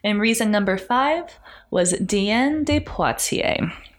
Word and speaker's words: Diane: But Diane, it's spelled Diane, but I'm trying to be French.